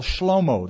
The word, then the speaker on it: Shlomo: How are you getting along with Uncle Shlomo? That's the Hebrew pronunciation.